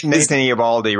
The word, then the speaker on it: Eovaldi: Nathan Eovaldi, right from the um, Boston Red Sox.